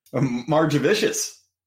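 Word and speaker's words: Margevicius: How do I say Nick Margivicus? – Margivicious.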